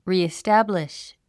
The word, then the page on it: reestablish: rìistǽbliʃ; リィスタブリッシュ